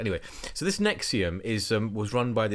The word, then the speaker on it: NXIVM: Now, Nixivum is spelled NXIVM. Actually, it says here that you're supposed to pronounce it Nexium, but I think it's a lot more fun if you say it Nixivum.